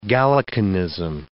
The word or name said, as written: Gallicanism